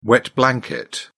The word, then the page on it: wet blanket: British English